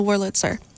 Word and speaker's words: Wurlitzer: The one with the Wurlitzer on Radio K, Real Emo Radio.